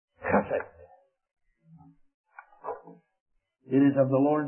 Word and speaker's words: chesed: Now, the Hebrew word chesed. You have a little saliva in your mouth to say it right. The Lord's loving kindness, chesed, which is the Old Testament parallel to the New Testament mercy, that we are not consumed.